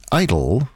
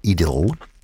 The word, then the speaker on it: idyll: An idyll, or if you prefer the English pronunciation, an idyll, in other words, something idyllic, which can be any form of art.